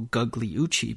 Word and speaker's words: Gugliucci: Now, her name looks like it's pronounced Nicole Gugliucci because she's got that silent G, and that silent G is rare.